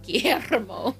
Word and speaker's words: Guillermo: Hey, you can roll your R there. – Guillermo.